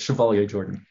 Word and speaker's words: Chevalier-Jordan: I hope that I pronounced that right. – Almost. It's Chevalier-Jordan.